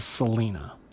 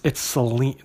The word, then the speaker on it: Selene: I've heard a lot of people throughout the years I've collected books, and I've said it most of my life as Selena. And I've heard others refer to her in the same way. I got to looking at it last time, I thought Selene seems more like that would be the correct pronunciation. And then when I looked it up online, it's Selene, they say it's Selene, with a long E, Selene.